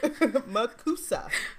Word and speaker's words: MACUSA: It's a soft S. MACUSA.